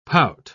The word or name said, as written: pout